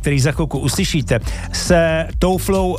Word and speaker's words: Tove Lo: Ano, teďka, když se tady jsme tady řešili tu výslovnost těch švédských jmen, tak já jsem slyšel, že se říká i Tuvelu. – Tuvelu? – Ale Tuvelu, a to je asi ve švédštině, ale my říkáme Tove Lo, protože samozřejmě používáme tu anglickou deskripci.